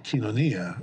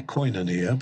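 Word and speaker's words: kinonia (κοινωνία): Even the way we think about words that are essential to Christian, like kinonia, or, well, if you have a barbaric Erasmian pronunciation, you'd say koinonia, but kinonia, you know, commonality, community.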